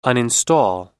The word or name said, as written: uninstall